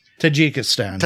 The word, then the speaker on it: Tajikistan: Tajikistan? – Tajikistan.